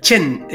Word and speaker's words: Qin: Qin, I can't speak Chinese, obviously, but Qin is usually spelt, if you see it in a Western book, Q-U-I-N. But sometimes you'll see it C-H-N-C-H-I-N, spelt various ways, but it's usually Q-U-I-N, and it's pronounced Qin, Qin Shi Huang.